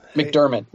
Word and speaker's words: McDiarmid: Am I saying that right? Ian? – McDiarmid. It's cool. Yeah, you're close.